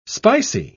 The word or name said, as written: spicy